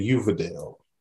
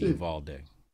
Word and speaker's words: Uvalde: No, this is a Hispanic place. That's day. It's not D. Gotta be Uvalde. – He said Uvalde.